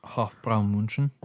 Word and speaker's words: Hofbräu München: Hofbräu München.